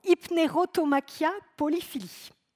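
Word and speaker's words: Hypnerotomachia Poliphili: Je vous l'ai prononcé tout à l'heure, hein, euh, Hypnerotomachia polyphilie. Vous avez peut-être eu l'impression que je me prenais pour Mary Poppins, là, vous savez, super califragilix, là. non, hypnérotomachia polyphilie.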